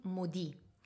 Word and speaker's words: Modi: When he was in Paris, everybody was calling him Modi. Modi that comes from Modigliani, no? But Modi, just written in another way, but with the same pronunciation in French, means maledetto.